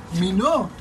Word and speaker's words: Minogue: Minó. Kylie Minó. – ¿Y cómo se escribe Kylie Minó? Minó. No sé cómo se escribe. – Minó. Es como francés, porque es canadiense también.